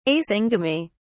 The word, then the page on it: asyngamy: British English